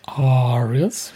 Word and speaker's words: ARIAs: It's less than a month to go now until we'll be at the London Palladium for the ARIAs. See, I'm learning how to say it posh. – ARIAs. Well done.